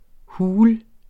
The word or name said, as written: hul